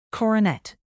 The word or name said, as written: coronet